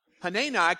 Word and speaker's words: Hanani: A seer, S-E-E-R, or a prophet, if you will, named Hanani, that's how you say it, Hanani came to Asa and confronted him about what he had done.